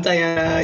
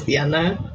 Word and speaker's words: Dianna: I'm Diana. You can also call me the Spanish pronunciation, which is Diana.